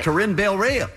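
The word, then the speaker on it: Corinne Bailey Rae: Corinne Bailey-Rea.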